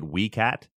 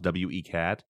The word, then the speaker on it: W.E. Cat: I keep calling it Wee Cat. The street is actually, because somebody pointed out to me, it's named after a person. It's W.E. Cat. So I do know that it's pronounced W.E. Cat.